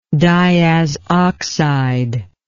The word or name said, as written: diazoxide